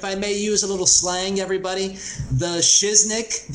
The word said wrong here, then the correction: shiznit: Shiznit. Knit. – Shiznit? – Like N-I-T. Shiznit. Well, the word is shit. – I mean, shiznick. – It's shiznit.